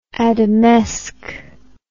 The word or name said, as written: Adamesque